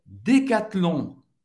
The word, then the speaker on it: Decathlon: A gente vai pronunciar o T, o N em francês tem o som do ON. Decathlon. Decathlon. Decathlon. O H e mudo, obviamente.